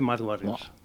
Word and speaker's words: Mud Warriors: ¿Cómo se pronuncia? – Guerreros del Barro, y se dice Mud Warriors en inglés.